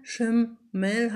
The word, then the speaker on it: Schimmel: Oder der Schimmel, Schimmel, Schimmel hat ein kurzes I. Hinter dem I steht noch ein M auf der ersten Silbe.